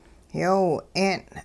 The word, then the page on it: yoo 'een: hupa